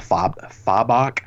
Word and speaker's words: Fabok: I too watched the Three Jokers panel with Jeff Johns and Jason Fabok, or as they pronounced it on in the panel, Fabok.